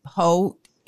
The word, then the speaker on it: Heaux: And ho, it's spelled for those who are super, y'all are prudes. It's spelled H E a U X, and it's tails like tails from the crib.